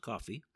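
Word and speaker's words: Ko-fi: and I am told it is pronounced coffee, and that's at coffee, and I am DocCross4591.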